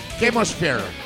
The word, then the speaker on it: Chemosphere: No digas che, di que. – Chemosphere. – Casi, Chemosphere. – Chemosphere.